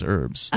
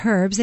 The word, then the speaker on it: herbs: I think they would be saying it incorrectly. It is herbs. – Oh, it's herbs. It has an H.